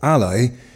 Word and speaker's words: AALI: Okay, the Animated Arts Learning Institute, AALI, pronounced as Ally,